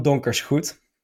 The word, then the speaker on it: Donkersgoed: So it ends in O-E-D, which in Dutch you pronounce as Luke van Donkersgoed. So the G-O-E-D is the same as good, G-O-O-D, in English. So my Twitter handle is Donkersgoed with O-O-D.